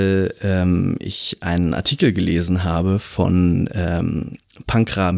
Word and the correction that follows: Pankaj: Mishra, oder wie würdest du ihn nochmal aussprechen? – Ähm, habe ich den Namen gerade hier stehen? Pankaj. – Pankaj, würdest du sagen, ja.